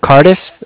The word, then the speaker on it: Cardiff: Cardiff. It's spelled C-A-R-D-I-F-F, like Frank Frank.